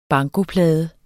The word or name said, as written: bankoplade